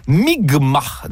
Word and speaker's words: Mi'kmaq: nation Micmac, qui se prononce en fait Mi'kmaq, dans ce qui sera le premier contact.